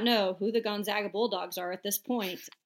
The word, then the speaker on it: Gonzaga: It's Gonzaga.